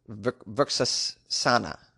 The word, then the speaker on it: Vrikshasana: For instance, tree pose instead of Vrikshasana, I've been informed is the way you pronounce that.